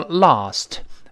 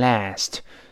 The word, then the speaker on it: last: 那你昨天晚上给小笨笨打电话了没有啊？Last night，这个 last 鲜明的美音，英国人读成 lost，美音中啊变成 a 读成 last，最后那个 t 失去爆破，因为 t 是爆破音，night 又是另外一个辅音呢开头的，失去爆破了。 Last，last 那个 t 没有了。